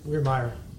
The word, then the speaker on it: Wehrmeyer: Did I pronounce that right? – Wehrmeyer. – Wehrmeyer. – Just like it's spelled.